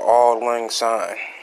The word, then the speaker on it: Auld Lang Syne: If I go to church, New Year's Eve song is called Auld Lang Syne. – Okay, thank you for pronouncing that correctly, because I did not know that was it.